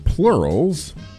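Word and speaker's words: PLRLS: And before that, it's pronounced plurals, but it's P-L-R-L-S with the song Curses from their 2023 Curses album.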